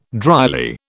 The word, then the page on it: dryly: British English